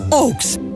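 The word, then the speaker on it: AUX: Αντλίε θερμότητα OX. Αθόρυβη λειτουργία, εγγυημένη απόδοση, ιδανική τιμή. OX. Προσοχή στο αξόν. A-U-X. Ε, Γράφεται με τρία γράμματα, προφέρεται με δύο και έχει λύσει για όλου.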